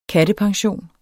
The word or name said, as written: kattepension